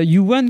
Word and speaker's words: YouWan: Et euh, You1 comme You Are the One, mais ça s'écrit Y-O-U-W-A-N.